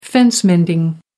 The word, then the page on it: fence mending: American English